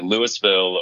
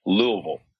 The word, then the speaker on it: Louisville: You know, when you arrive in the airplane, you can know if someone is from there or knows someone there, because they either say Louisville or they pronounce it properly, which is Louisville. So you kind of swallow the vowel right there in the beginning.